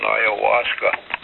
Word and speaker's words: ayahuasca: Yes, I've read brief reports on crocodile, and that's also, it's probably even worse than ayahuasca. – Yeah, I'm surprised you got the pronunciation right, because I still can't spell it. Something like A-U-Y-A-U-S-C-A, but don't quote me on ayahuasca.